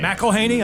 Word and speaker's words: McElhenney: Ryan Reynolds and Rob McElhenney, I don't know how you say his name. – McElhenney, I believe. – I think that's right, yeah.